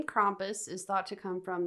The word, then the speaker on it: Krampus: Well, Krampus, not Krampus, like some people I've heard say his name, which is just like, oh, it sounds so bad. It's Krampus.